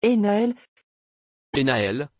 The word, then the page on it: énaël: French